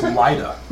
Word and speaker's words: Lida: L-I-D-A. – Oh, I've been spelling it wrong. – It's not Lolita. – So it's Lida. – It's pronounced Lita because it's a more. – Well, I'm saying Lida.